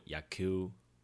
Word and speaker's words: 野球: 棒球在日文念作 yaku，写成汉字呢是野球，也是田野调查的野。